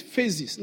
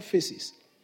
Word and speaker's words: phases: You know, it's phases, not faces.